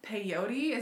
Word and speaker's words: peyote: So of course the next logical thing is she starts taking a ton of LSD, and mescaline and peyote. Is that how you say it? – Yeah.